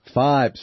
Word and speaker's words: FYBE: Am I saying this right? Fiber? – Five. – Sorry. – I believe. Yeah. Yes. FYBE.